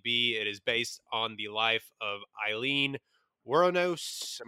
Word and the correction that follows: Wuornos: I'm going to mispronounce that all night. Woronos. – Uh, War- Wuornos, a Daytona Beach prostitute who became a serial killer.